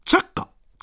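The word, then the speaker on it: 来過: その名前が、来て過ごすと書いて、チャッカ。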